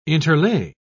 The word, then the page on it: interlay: British English